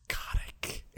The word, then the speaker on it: Kotick: It's pronounced Kotick.